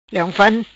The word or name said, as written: Leong Fan